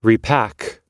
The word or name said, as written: repack